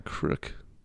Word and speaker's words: creek: It was kind of in a crick. – In a what? – In a crick. – Creek? – Yeah, creek. – Nope, that's a creek.